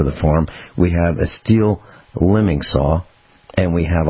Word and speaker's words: Stihl: steel? And that's STIHL, pronounced steel.